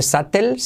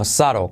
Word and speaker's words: subtle: No se dice Satel, sino Saro, como el marido, el masculino de Sara, Saro, ¿vale? Saro. No se pronuncia la B, obviamente.